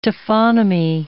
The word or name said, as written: taphonomy